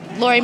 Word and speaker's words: Lori: Lori Mouse. – How do you spell that? – L-O-R-I-M-I-L-E-S.